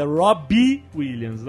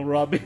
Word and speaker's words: Robbie Williams: Aliás, eu falei errado, é Robbie Williams, não Robbie.